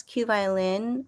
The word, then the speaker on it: QVLN: He's also known as Q Violin, which is spelled QVLN.